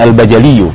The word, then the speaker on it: Al-Bajali: Bajali. – Iya. Yeah. Oh, berarti benar bacanya tadi ya. Ibnu Abdillah Al-Bajali, iya. Yeah. Oh, tanpa tasdid di dalamnya ya. Enggak pakai tasdid ya, berarti Al-Bajaliyu, ya, yeah, Al-Bajaliyu.